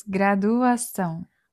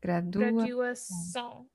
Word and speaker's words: graduação: So it is graduação. – Oh, so the D is a hard D. Graduação. – Yeah, and the emphasis is on the last syllable, so it's graduação.